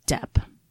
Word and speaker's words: Doep: When she found them, she took them to her employer, who we only know by the name of Depp, but it's spelled D-O-E-P.